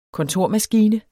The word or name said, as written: kontormaskine